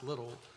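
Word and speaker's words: Liddell: His name looks like Lydell to me, but it's pronounced Little, I believe.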